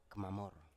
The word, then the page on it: kmamor: Biak